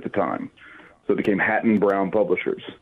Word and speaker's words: Hatton-Brown: And did I say that right? I think I did. – No, it's Hatton Brown. Hatton Brown Publishers.